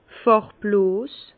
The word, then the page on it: fàrbloos: Bas-Rhin; Herrlisheim